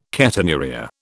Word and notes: ketonuria: British English

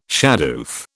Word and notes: shadoof: British English

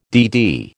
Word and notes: DD: American English

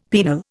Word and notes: penal: Indian

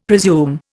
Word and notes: presume: Indian

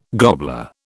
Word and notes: gobbler: British English

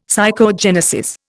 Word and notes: psychogenesis: Indian

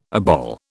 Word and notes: obol: British English

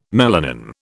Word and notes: melanin: British English

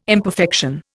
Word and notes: imperfection: Indian

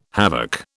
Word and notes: havoc: British English

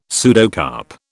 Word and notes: pseudocarp: British English

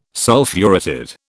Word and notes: Sulphuretted: British English

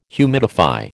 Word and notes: humidify: American English

